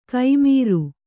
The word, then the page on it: ಕೈಮೀರು: kai mīru; Kannada